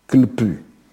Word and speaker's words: Klpu: Klpu. Je prononce ça comme ça, ça s'écrit K-L-P-U.